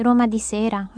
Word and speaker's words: Roma di sera: I'd say Roma di sera, Roma di notte.